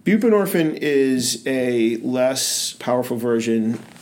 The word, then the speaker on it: buprenorphine: Buprenorphine.